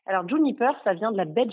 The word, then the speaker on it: JNPR: Réinventer sa façon de consommer au moment de l'apéro, c'est tout un concept que propose Valérie de Sutter, créatrice de la marque Juniper, euh, qui s'écrit JNPR.